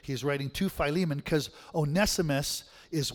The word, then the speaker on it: Onesimus: Onesimus. That's how I heard it in college, right? Some say Onesimus, but I don't think I like the long E.